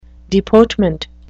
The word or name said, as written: deportment